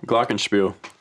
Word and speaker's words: Glockenspiel: Did I say it right? Glockenspiel? – Glockenspiel.